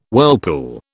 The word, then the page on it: whirlpool: British English